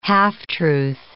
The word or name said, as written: half-truth